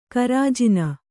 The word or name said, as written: ಗಿರಿಜನ